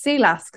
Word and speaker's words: Silas: Because I haven't really heard about the book or anything, but that's pronounced Silas because my sister's boyfriend is Danish and he's called Silas and it's spelled that way.